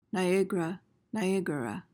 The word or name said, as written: niagara